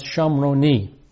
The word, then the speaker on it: Shamroni: Well, the Hebrew word for Samaritan is pronounced Shamroni.